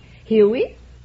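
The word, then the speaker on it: Hewie: No, it's H-E-W-I-E. It's the syllable I say. Huey. You nothing. – Let your tongue be loose inside and just say Huey.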